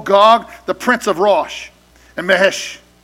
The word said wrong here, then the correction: Meshech: How do you say that? Meshech. Meshech and Tubal.